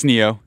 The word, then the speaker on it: Ne-Yo: What's N-E-Y-O? What is that? Singer, rapper. Yeah, what's his? How do you pronounce his name? Neo. – Yeah, it's Neo.